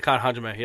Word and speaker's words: Kata Hajime: Taz gets the choke out, the Kata Hajime. I think I'm saying that right. – Yeah, Kata Hajime, yeah.